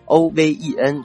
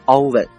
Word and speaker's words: oven: 有同学看到 O V E N 直接读成 oven。错了啊，它也没有英美音的区别，这个词呢只有一个读音，叫做 oven。